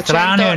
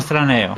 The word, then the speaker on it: Straneo: Non solo l'ha detto giusto, ma ha detto anche con la pronuncia giusta. – Sì, Straneo e non Straneo. – L'accento brava. Corretto sul cognome.